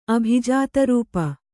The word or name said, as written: ಅಭಿಜಾತರೂಪ